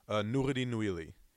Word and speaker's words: Nouredin Nouili: Nouredin Nouili.